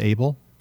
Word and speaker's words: AAEEBL: It's abbreviated AAEEBL, and they pronounce it ABLE.